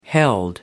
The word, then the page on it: held: American English